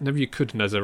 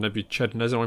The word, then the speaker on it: Nebuchadnezzar: It's Nebuchadnezzar rather than Nebuchadnezzar, I believe.